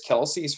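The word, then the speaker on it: Kelce: Yeah, the story that I read said that from Kelsey specifically, he talked about how his dad originally was pronounced Kels, like that's how his family pronounces it, Kels, but the place that his father worked at, everyone said Kelsey, so he just got tired of it and had it legally changed, which led to a comment I saw on Twitter, which I thought was the best thing ever.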